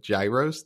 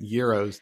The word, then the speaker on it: gyros: loved, because this happens all the time, he said it gyros to make one laugh or one yes. line, and then he went euros to rhyme with heroes, and I was like, this is greatness.